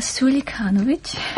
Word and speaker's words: Suljkanovic: Eldina Sulikanovic.